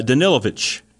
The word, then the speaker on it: Danilovich: Uh, am I saying this right, Danowitz? – Uh, Danilovich is how he Danilovich. says it with the V sound.